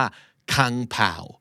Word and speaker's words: Kung Pao: K U N G P A O แต่จริงๆแล้วมันอ่านออกเสียงว่าคังเผาคังเผาเจ้าของภาษาออกเสียงแบบนี้